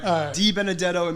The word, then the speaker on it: DiBenedetto: You said it right. DiBenedetto, it means of good words, I think, in Italian.